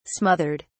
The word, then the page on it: smothered: British English